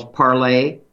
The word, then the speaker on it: Parler: It's called, I don't know if they pronounce themselves, parler, the French verb to speak. Parlez-vous français, mademoiselle? Or parler, P-A-R-L-E-R, the way we would pronounce it in English, parler.